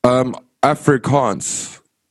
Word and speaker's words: Afrikaans: It's spelled Afrikaans. So it's like almost like Africa, but it's Afrikaans.